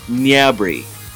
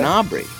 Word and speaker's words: Gnabry: It's if the French, the way the French pronunciation ends up being is Serge Gnabry. – Okay. – So it's like it's Nya at the beginning. – Yeah. – But that's if you're French. The Germans do give it a more of a G pronunciation.